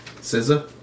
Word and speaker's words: SZA: How do you say her name? – SZA? – Yeah.